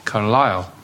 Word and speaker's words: Carlisle: Well, my parents, who were Scottish, always pronounced it as Carlisle, and we read the Scottish Daily Express and the Sunday Post.